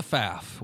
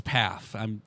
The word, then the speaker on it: Pfaff: So what the faff? What the paff? Right, Brad Pfaff. It's Pfaff.